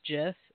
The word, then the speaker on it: GIF: is actually pronounced GIF, just FYI. – Okay, so why is the peanut butter J-I-F? – Because it's also pronounced GIF.